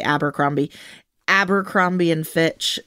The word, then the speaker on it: Abercrombie: Abercrombie.